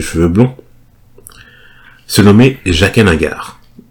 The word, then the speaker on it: H'ghar: En tout cas, on dit H'ghar, parce que je crois que dans la série, il dit Jaqen H'ghar. C'est vrai que quand tu lis ça et que t'as jamais entendu la prononciation...